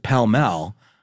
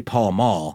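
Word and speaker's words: Pall Mall: But grandpas pronounce it pell-mell. And if you look at the pack, it's clearly Paul Mall. – Yeah. – And they're still around. But apparently, they're like, that's one of these examples of willful disagreement of an obvious pronunciation.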